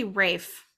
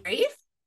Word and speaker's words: Ralph: It's actually Rafe. I looked this up. – Rafe? – That is how you say his name. Rafe Fiennes. Or Fiennes. – Oh, Rafe Fiennes. I was like, it's Ralph. And no one in interviews calls him that. They all say Rafe.